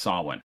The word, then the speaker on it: Samhain: It's pronounced slightly differently depending on where you are, but it's never spelled like it sounds. It's Samhain. It looks like Samhain, but it's pronounced Samhain.